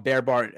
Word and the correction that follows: Breitbart: I mean, I'm like, how do you say that name? – Breitbart. – Thank you. Breitbart, yes, I got you.